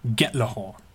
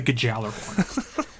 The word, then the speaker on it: Gjallarhorn: They had somebody who speaks native Icelandic, and they talked about the pronunciation, and for them in Icelandic, and it is Gjallarhorn.